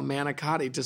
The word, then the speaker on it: manicotti: I was looking up how to spell manicotti so that I could tell your mom that there's an extra syllable that she leaves out.